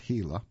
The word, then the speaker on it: Gila: And by the way, the reason I mentioned the pronunciation, it's spelled G-I-L-A, but again pronounced Gila.